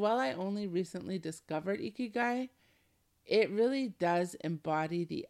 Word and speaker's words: ikigai: Ikigai. It's hard to say because I'm not Japanese, but I love it. It's spelled I-K-I-G-A-I, ikigai, okay?